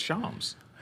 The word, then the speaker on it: Shams: Sham Sharania of The Athletic. In fact, I think it's Shams now. – It's Shams.